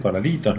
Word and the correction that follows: cornalitos: no. – Eh. – Cornalitos. – Cornalitos, gracias, no me salía la palabra.